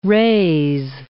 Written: raze

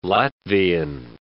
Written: Latvian

